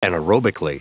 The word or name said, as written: anaerobically